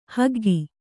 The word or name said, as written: ಹೊಗ್ಗು